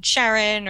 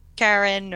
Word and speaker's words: Charon: And Charon or Charon, or I've honestly heard it pronounced both ways by experts in the field, so I am not picky about pronunciation.